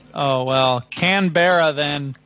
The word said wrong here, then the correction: Canberra: Well, how do you say it? – Canberra. – The capital of Australia. – It's a capital of Australia. – Because if you get it wrong, people make fun of you. The way to deal with it is like, no, sorry, dude, you're saying it wrong. The way to say it is Canberra.